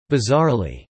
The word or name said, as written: bizarrely